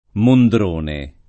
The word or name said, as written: Mondrone